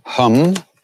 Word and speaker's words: hum: Om hum. Like I hum a tune. Although in India that's a short A.